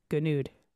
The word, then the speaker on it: Gonoude: Gonoude.